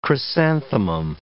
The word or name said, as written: chrysanthemum